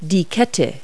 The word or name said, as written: chain